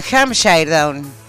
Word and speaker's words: Hampshire Down: Pero en el marco de la 138 Exposición Rural de Palermo, se llevó el primer premio del Congreso Mundial de Sharpen Down. ¿Se dice así? Usted que sí, conoce supongo. Razas, sí. Esta es la raza campeona, es una oveja, obviamente. – Hampshire Down. – Eso, usted lo pronuncia mejor que yo.